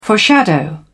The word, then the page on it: foreshadow: British English